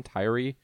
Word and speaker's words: Tyree: Is that how you pronounce his middle name? Tyree? – Yes.